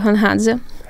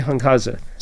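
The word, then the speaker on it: Hunhadze (Ґонґадзе): I don't want to mispronounce his name. – His name is Hrori Hunhadze. – Hrori Hunhadze. – Yes. – A version of Gregory. – Gregory, yes. – Hrori Gunhadze.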